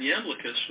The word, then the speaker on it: Iamblichus: a fellow with the exotic name Iamblichus. And for the next spelling bee, that is spelled I-A-M-B-L-I-C-H-U-S, Iamblichus.